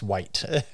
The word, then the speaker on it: wight: I think it's pronounced white. W-I-G-H-T.